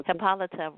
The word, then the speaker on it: Hippolyta: And then Hippolyta, is how you pronounce the character's name? – Hippolyta, right.